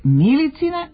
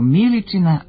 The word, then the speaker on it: Miličina: godinama ne mogu da zapamte i da se ne kaže Milicina, nego Miličina medalja.